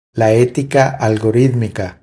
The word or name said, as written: La etica algoritmica